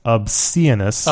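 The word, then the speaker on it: Obsianus: but there's no D in there, and so it's O-B-S-I-A-N-U-S, Obsidianus.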